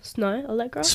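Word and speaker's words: Snoh Aalegra: Snow Allegra. – If I'm pronouncing that wrong, someone let me know, please. – No, it sounds right. I don't know.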